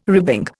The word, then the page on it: ribbing: Indian